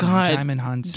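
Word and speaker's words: Djimon Hounsou: So we were saying his name wrong. So it's Dai Mahunso. – No, it's Jai. – But I believe it's silent. Jaimon Hunso.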